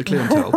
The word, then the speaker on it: clientele: Because do you say clientele? – Clientele, yeah. I mean, I know. I don't think I actually knew until a few years ago that's what it was meant to be.